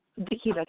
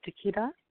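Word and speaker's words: Daakieta: Dikita, can you hear me? – Yeah, it's very garbled. – Different room. – Okay, wow. – just talk real slowly and clearly. – Okay. My name is Dikita.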